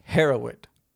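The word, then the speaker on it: Hereward: is his name was Harrowood. – Harrowood? – Harrowood. – Yeah. – Sounds fine. – Yeah. – It's spelt here, H-E-R-E. – Yep. – Ward, W-A-R-D. – Harrowood.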